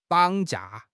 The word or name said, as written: 绑架